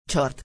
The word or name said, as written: short